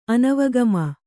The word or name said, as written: ಅನವಗಮ